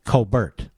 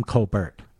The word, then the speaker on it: Colbert: He pronounces his last name, I swear to you, that Colbert. I think it's Tom Colbert. And by everything I hear, he's a pretty good guy. So now we have Stephen Colbert, where, you know, half his name is silent.